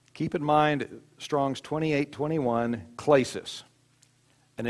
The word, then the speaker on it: klesis: It's spelled K-L-E-S-I-S, klesis.